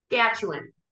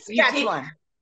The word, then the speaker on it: Saskatchewan: So say it again so I know the proper pronunciation. – Saskatchewan. – What? Like spell Saskatchewan or no, Saskatchewan. The whole middle part isn't supposed to be there. – Saskatchewan. – You're doing too much.